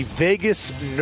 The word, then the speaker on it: vagus: He gives advice by saying you have to activate the vagus nerve. All right, the vagus nerve, and it's not spelled like our favorite city, Las Vegas.